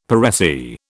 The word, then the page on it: per se: British English